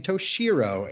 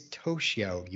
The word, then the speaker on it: Toshio: And by the way, speaking of pronunciation, this entire season I've been calling him Toshiro. And then I scrutinized it in the script and I was like, it's Toshio, you moron.